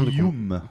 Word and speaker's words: Paprium: Voilà, comme ça se prononce, euh, rien de. – Rien Paprium. pas Paprium. Voilà, voilà.